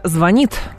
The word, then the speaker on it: звонит: Но и в конце обсудим ударение в слове «звонит» в будущем может измениться, точнее, не может, а просто изменится, и будет падать на первый слог.